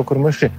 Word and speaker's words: курмыши: Обычно такие слова имеют ударение на последний слог. Сегодня как раз пытался это выяснить. Скорее решил, что курмыши.